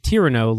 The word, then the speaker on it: Tyranno: I got the reference, but I mispronounced it because my brain just said, I was saying Tyranno. And then JPG was like, oh, no, it's supposed to be like, it's Tyranno, like Cyrano. Like Cyrano.